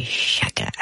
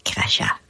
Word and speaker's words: chakra: C'est chakra. Pas dire crachat.